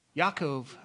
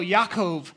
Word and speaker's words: Yaakov: I've really enjoyed actually teaching on James, or as my father-in-law likes to remind you and me, his name is Jacob. What? – Yaakov. – I don't even know how to say it. So Yaakov. So it's Jacob, basically. His original name was Yaakov. If he was here and speaking Hebrew, he would say that.